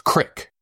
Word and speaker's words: creek: Just like the little small piece of moving water, it's spelled C-R-E-E-K, but it's pronounced crick. Yeah, it's not a creek.